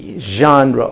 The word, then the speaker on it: genre: And it's spelled, I think, G-E-N-R-E, genre, or for you Texans, genre.